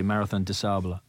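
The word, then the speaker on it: Marathon des Sables: do I pronounce this correctly, Marathon du Sable? – A very good pronunciation, I'd say.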